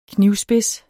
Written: knivspids

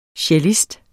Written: CELLISTEN

